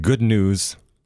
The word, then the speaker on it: Gudnuis: See, I was going to write the word out and you wouldn't, it's spelled G-U-D-N-U-I-S, mm-hmm. N-U-I-S, newest, mm-hmm. newest. But when you say it, good news, and it sounds the way it should.